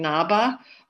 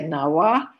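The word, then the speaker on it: Ngaba: Yeah, there's lots of different pronunciations. I'm saying Naba. A lot of Tibetans say Nawa, Chinese say Abba. Well, why don't we stick with Naba? But there's, depending on which dialect you're using.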